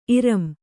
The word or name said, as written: ಇರಂ